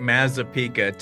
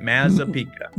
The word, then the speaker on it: Mazzapica: You know what? You did fantastic. Mazzapica. People need to practice it like 10 times, but you nailed it. Mazzapica.